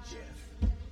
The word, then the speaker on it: Geoff: Or Geoff. But I'm pretty sure it's always pronounced Jeff.